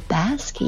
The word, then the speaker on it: bask: 注意一下这个 bask, B-A-S-K, 它可以读作 bask, 也可以读作 bask, 它是表示享受、陶醉、沉浸的意思。